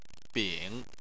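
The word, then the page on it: bing: tone 3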